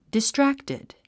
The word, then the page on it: distracted: distrǽktid; ディストラクテド